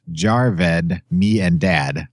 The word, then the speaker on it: Javed Miandad: And the Pakistani cricketer you attempted to name, Javed Miandad, is pronounced Jarved Me and Dad.